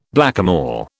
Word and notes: blackamoor: British English

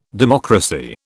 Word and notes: democracy: British English

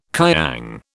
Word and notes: kiang: British English

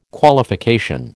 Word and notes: Qualification: American English

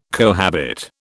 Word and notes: cohabit: British English

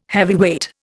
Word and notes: heavyweight: Indian

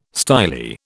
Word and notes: styli: British English

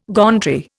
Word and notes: gauntry: Indian

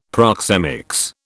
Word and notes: proxemics: British English

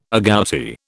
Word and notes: agouti: British English

